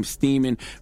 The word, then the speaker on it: Steeman: Let the record show that his last name, Steeman, rhymes with semen for a reason.